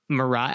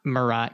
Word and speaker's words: Marat: Death, a noble pose, Marat. I'm guessing it's not Marat because it's French.